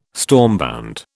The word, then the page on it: stormbound: British English